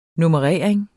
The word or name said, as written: nummerering